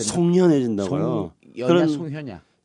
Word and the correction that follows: 송연해진다: 묘골이 송연해진다 이래야죠. 묘 묘골? – 모골이 송연해진다. 모골이 송연해진다.